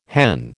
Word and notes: hen: American English

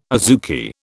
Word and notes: azuki: British English

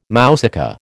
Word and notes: mousaka: American English

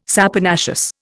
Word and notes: saponaceous: Indian

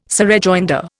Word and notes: surrejoinder: Indian

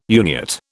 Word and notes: Uniat: British English

